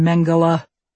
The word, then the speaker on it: Mengele: When you mispronounce his name and he tells you it's a hard G in Mengele, he makes you do your own pelvic exam while he watches.